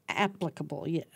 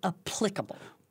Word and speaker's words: applicable: Applicable, yes, not applicable. – Right. – When people say applicable, that's wrong. – Right. Applicable. – Yeah, applicable.